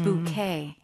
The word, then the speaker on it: bouquet: Oh, it's very similar. It's just bouquet.